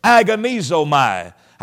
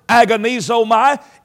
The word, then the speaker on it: agonizomai: The word translated strive in the Greek language is pronounced like this, agonizomai.